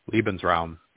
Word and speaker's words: Lebensraum: I forget how you say it in German. – Lebensraum, yeah.